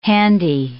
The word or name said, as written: handy